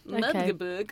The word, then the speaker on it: Magdeburg: Magdeburg.